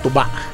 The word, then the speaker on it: tuba: O la pronunciamos tuba. – Tuba. – sí. Con acento. – el segundo, sí.